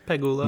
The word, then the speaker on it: Pegula: We have Serena at 8, Jennifer Brady at 15, Coco at 23, Jessica Pegula at 25. Did I pronounce that correctly? – Yeah. – I think I did. – Close enough. Pegula.